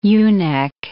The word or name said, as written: ewe-neck